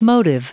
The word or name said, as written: motive